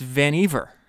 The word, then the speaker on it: Vannevar: In Isaacson says, he writes what what I was reading is Vannevar, and he says rhymes with beaver. It's Vannevar, Vannevar Bush.